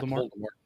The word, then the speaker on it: Voldemort: Did I say that right? Voldemort? – Yeah. – Voldemort.